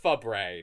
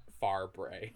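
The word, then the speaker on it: Fabray: Yeah, it's Quinn Fabre, not Farbre.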